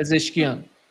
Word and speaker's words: Pezeshkian: President Pezeshkian, is that how he pronounces it? – Yeah, Pezeshkian.